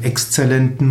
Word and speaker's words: exzellent: Exzellent kommt vom französischen excellent, folgt aber im Deutschen der lateinischen Aussprache, kommt von excellere und das heißt hervorragend, sich auszeichnen.